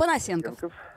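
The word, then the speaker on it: Понасенков: И у нас на связи московский эксперт, историк Евгений Панасенков. Евгений Николаевич, здрасте, слышите ли вы нас? Добрый день. – Здравствуйте, слышу, что неправильное ударение. – Панасенков.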